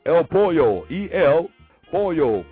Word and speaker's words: pollo: P-O-L-L-O. Of course, you know that when you have the double L's, you normally say yo. You know what I'm saying? You pronounce it with a Y. So it's pollo, like me llama, me llama, me llamo. You know what I'm saying? You don't say polo, or me llamo.